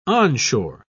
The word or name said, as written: onshore